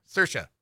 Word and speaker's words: Saoirse: I can never pronounce her name right. She is Irish. Charisse, right? Roynan? – Saoirse.